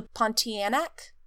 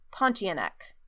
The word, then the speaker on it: Pontianak: So I apologize for butchering the pronunciation, but I believe this is called the Pontianak. Pontianak. P-O-N-T-I-A-N-A-K.